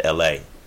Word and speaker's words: ELHAE: His name is LA, but it's spelled ELHAE.